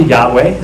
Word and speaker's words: Yahweh: so it's probably Yahweh is the correct pronunciation of it.